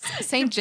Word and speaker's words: St. James: Ob er Lust hätte, mit ihm zu St. James Hall zu gehen. – St. James. You butchered that so hard.